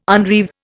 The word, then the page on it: unreeve: Indian